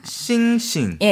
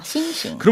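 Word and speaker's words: 싱싱 (星星): 싱싱. – 예, 별 성자가 싱일성이거든요. 네. 그러니까 두 번 발음해서 한 번은 일성, 한 번은 경성. – 네. – 그래서 싱싱. 그렇죠. 네. 싱싱.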